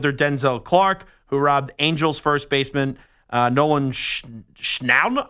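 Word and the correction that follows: Schanuel: How do you say that last name? Nolan Schanwell of a home run last night with one of the greatest catches that you'll ever see.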